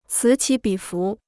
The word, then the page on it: 此起彼伏: cǐ qǐ bǐ fú